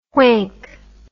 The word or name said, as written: whank